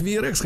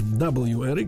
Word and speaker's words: WRX: На четвертом месте Subaru V, э, ну, его называют VRX, хотя правильно говорить WRX.